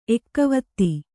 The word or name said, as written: ಎಕ್ಕವತ್ತಿ